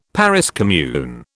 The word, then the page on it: Paris commune: British English